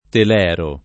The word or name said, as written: telero